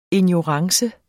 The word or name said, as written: ignorance